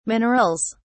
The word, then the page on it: minerals: British English